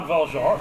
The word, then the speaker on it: Jean Valjean: Jean Valjean.